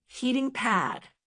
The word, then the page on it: heating pad: American English